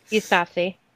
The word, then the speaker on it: Yousafzai: There was Malala, whose last name I can never pronounce. – Yousafzai. – That's it.